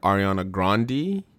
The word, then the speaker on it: Ariana Grande: Well, apparently her name is Ariana Grande, so we gotta say it right.